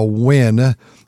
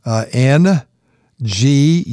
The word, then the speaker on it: Nguyen: We have a wonderful song, and I've introduced these girls before, the Wynn girls, N, G-U-Y-E-N, and that's pronounced Nguyen, from Vietnam.